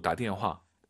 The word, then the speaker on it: 打电话: 발음하실 때 다댄, 너무 다댄 하지 마시고 힘 빼시고 다댄화.